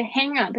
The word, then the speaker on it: hang up: 接下来是 hang up，hang up，这个 hang up 大家要特别注意一下 ng 的发音是这个嗯，就是舌头的后面抬起来嗯的声音。